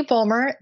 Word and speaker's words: Bulmer: My name is Katie Bulmer. That's Fulmer with a B.